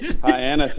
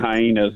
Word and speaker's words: Hyannis: Hyannis is like Hyannis. – Anis is the way to say it. Gary was saying it wrong.